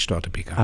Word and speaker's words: Störtebeker: Deshalb steht da auch tatsächlich ein B-E-K-E-R und kein B-E-C-K-E-R oder irgendwas ähnliches oder eben 2K, ja? Sondern dann wird der Vokal kurz gesprochen, so wird er langgezogen, heißt eigentlich Störtebeker.